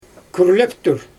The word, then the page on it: kürleptür: Xyzyl